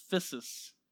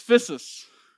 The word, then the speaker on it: physis: I think it's pronounced physis. It's used in uh, 2 Peter 1 4 and autocorrect change it to physics. It's physis.